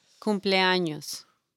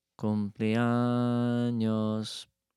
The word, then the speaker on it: cumpleaños: Cumpleaños. – Cumpleaños.